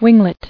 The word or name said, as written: Winglet